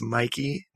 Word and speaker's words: Mikey: So my name is Mikey Needleman, and that's spelled M-I-K-E-Y.